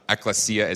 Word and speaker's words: Ekklesia: Ekklesia is how you pronounce that weird-looking word up there. There's not a misspelling.